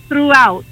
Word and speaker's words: throughout: Per esempio, ecco, la parola throughout, che c'ha il GH silenzioso in mezzo.